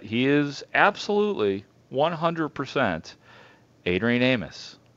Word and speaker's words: Amos: It is Adrian Amos. It is not Adrian Amos.